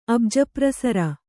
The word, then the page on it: ಅಬ್ಜಪ್ರಸರ: Kannada